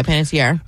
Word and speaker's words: Panettiere: I believe so, Panettiere.